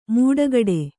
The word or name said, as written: ಮೂಡಗಡೆ